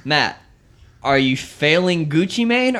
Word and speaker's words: Gucci Mane: Gucci Mane, is that how you say his name? – Yes, Gucci Mane.